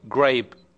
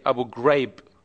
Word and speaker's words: Abu Ghraib: What I hear from, like, West media, they say Abu Ghraib, grape, as in, like, grape. I know that they can't pronounce the G, so they pronounce it G, Abu Ghraib. If they can't pronounce the G, that's okay, they can say, but instead they don't have to say it Abu Ghraib. All they have to say is Abu Ghraib.